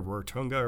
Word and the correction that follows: Rotorua: or whatever, wherever it is. Rotorua. I knew I said that wrong.